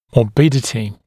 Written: morbidity

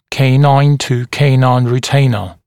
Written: canine to canine retainer

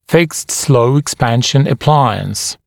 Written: fixed slow expansion appliance

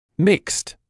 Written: mixed

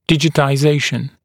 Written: digitization

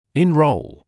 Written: enroll

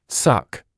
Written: suck

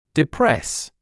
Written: depress

